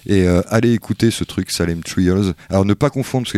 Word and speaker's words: Salem Trials: Il y a un problème sur ma prononciation. Ah oui, Salem Trials salem Trials. – Euh. C'est déjà mieux.